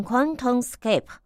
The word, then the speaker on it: QuantumScape: 好，这个名词呢，应该是念成 QuantumScape 啊。